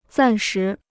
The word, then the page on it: 暂时: zàn shí